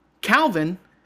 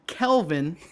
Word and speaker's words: Calvin: His name is Calvin, they pronounce it Kelvin, and you know, it's just a demographic thing. As soon as he goes to college, kids are going to call him Calvin. They'll be like, no, Kelvin.